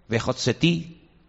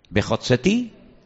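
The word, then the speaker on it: vehotseti: Sacaré, vejotsetí, libraré, vejizaltí, redimiré, vegaltí, yo tomaré velacachti. Las cuatro terminan en ti, ti, ti, ti. ¿Lo veis? ¿Podéis pronunciarlas conmigo? Bejotzeti, vejitzalti, vegalti, Velacachti.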